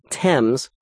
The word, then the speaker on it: Thames: spelling Thames, like the River Thames, T-H-A-M-E-S, with an H is a folly without warrant.